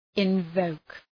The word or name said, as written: invoke